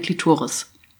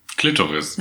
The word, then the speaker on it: Klitoris: Von innen konnte ich das Muster der Glasscheibe erkennen und es sieht für mich aus, ich habe ihm gesagt, wie eine Klitoris. Und Kai sagte... – Klitoris. – Das heißt Klitoris. Ich so, will es nicht verarschen, das heißt Klitoris. Also die Betonung mehr auf dem O.